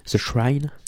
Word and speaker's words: The Shrine: S-H-R-I-N-E. The Shrine.